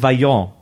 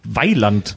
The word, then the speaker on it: Vaillant: und ich dachte mein Leben lang, die heißen Vaillant. – Aha. Weil man das nur gelesen hat mit V, aber das wird deutsch ausgesprochen. Weiland.